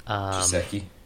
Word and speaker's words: Gesicki: Gesicki.